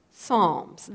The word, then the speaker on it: Psalms: Now, you might be a little confused because the word doesn't sound like it starts with an S. It starts with a P, but we pronounce it Psalms, the Psalms.